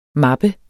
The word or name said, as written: mappe